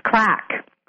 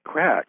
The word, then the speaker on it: craic: And the word that you're referring to in Irish is pronounced crack. – Crack, okay.